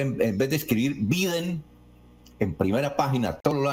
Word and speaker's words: Biden: Desde luego que está mal escrito el apellido en Biden, escribir Biden, porque es que Biden es la pronunciación, así se pronuncia Biden,